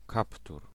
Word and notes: hood: /hʊd/; [hʊd]

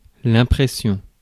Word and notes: effect: [ə.ˈfɛkt]; American English

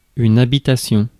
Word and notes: residence: American English; [ˈɹɛz.ɪ.dəns]

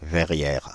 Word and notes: verrière: [vɛ.ʁjɛʁ]; /vɛ.ʁjɛʁ/

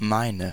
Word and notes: min: /miːn/; /mɪn/

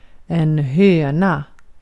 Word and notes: kana: /kɑ.nɑ/

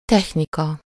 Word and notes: technika: /ˈtɛxnikɒ/

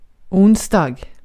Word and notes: onsdag: /ˈʊnsdɑːɡ/; /ˈʊnsda/

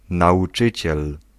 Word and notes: professeur: France (Paris); [ɛ̃.pʁɔ.fe.sœʁ]

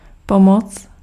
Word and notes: pomoc: /ˈpɔ.mɔt͡s/

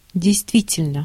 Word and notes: truly: /ˈtɹuːli/

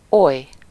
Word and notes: oly: /ˈoj/

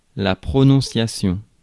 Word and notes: prononciation: France (Paris); [la pʁo.nɔ̃.sja.sjɔ̃]

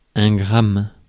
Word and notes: gramme: France; [ɛ̃ ɡʁam]

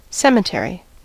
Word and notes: кладбище: [ˈkɫad.bʲɪ.ɕːɪ]

